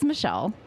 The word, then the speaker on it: Mechille: It's spelled M-E-C-H-I-L-L-E. How do you pronounce that? – It is Michelle.